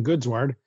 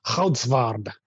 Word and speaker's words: Goodsward: I just say Ken Goodsward. But if you're, depending on how Dutch you are, I guess the proper pronunciation is more like Goudsward. But I don't expect anyone to be able to say that unless they are from another planet.